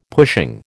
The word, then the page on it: pushing: American English